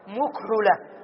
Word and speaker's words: مكحلة: ستقول مكحلة. لا، هذه شذت، شذت يعني العرب نطقوا بها هكذا فنحفظها هكذا، مكحلة.